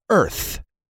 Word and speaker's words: earth: Al decirla, olvídate de la A. Y di earth.